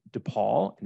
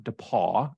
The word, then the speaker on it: DePaul: And Perteet has her BS from DePaul, not DePaul. I always try to make sure I say that correctly because people, it's almost like the Ohio State.